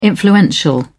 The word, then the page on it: influential: British English